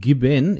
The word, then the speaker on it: Gießen: Now, excuse my pronunciation, Gießen. Is that correct? Have I pronounced that correctly? – Gießen. No, Gießen. – Gießen. Gießen. Okay.